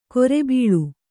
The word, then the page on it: ಕೊರೆಬೀಳು: kore bīḷu